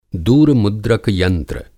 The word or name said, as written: दूरमुद्रक यंत्र